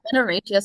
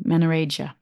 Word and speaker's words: menorrhagia: And you just mentioned, because you said when you were younger, you had, did you say menorrhagia? Did I pronounce that right? – Menorrhagia, so it's just like bleeding. So it's, yeah, it's like excessive bleeding. So it's meant to be like, you know, heavy menstrual bleeding, but when it goes like beyond the time frame for your menstrual cycle, when it's really heavy, when it's going on for weeks, when it's going on for months, you know, that's when you get diagnosed with menorrhagia. Yeah. – Okay. Sorry, I pronounced it completely wrong.